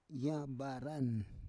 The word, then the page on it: yabaran: Roon